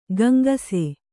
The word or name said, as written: ಗುಂಗಿಸು